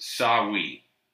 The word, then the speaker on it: Samhain: So historians agree that a Celtic autumn festival called, and I believe it's pronounced Samhain, or Samhain, but it's spelled S-A-M-H-A-I-N, Samhain, because I read this article the other day in search of a podcast idea.